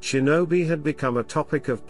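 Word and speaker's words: shinobi (忍び): In the native Kanyomi reading, it is pronounced shinobi, a shortened form of shinobi no mono.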